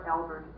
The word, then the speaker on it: Albert: Barbara Albert, A-L-B-E-R-T.